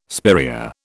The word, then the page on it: spiraea: British English